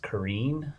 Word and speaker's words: Carine: And I always thought her name was Corinne, but apparently it's pronounced Carine, which I always thought she was called Carine McCandless.